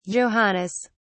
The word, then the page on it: johannes: British English